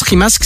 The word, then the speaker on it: Rimac: Ça se prononce Rimask, ça se lit Rimac.